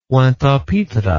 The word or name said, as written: pointe-a-pitre